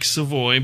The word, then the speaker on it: Savoy: Spell S-A-V-O-Y. It's spelled like Savoy, but I'm Cajun, so it's Savoy.